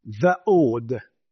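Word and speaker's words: va'od (וָעֶד): va'od. I'm putting a W between the A and the D because that's most probably how it should be pronounced. It's not ed, it's more od.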